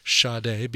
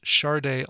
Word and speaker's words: Sade: Moving on to Sade, and I'm making sure to pronounce it Sade because during that first set, I looked it up. Epic Records was the record label that indeed put Sade, pronounced Sade, on the spines of the CDs that came out for Diamond Life. However, that was a mistake. Her name is really pronounced Sade.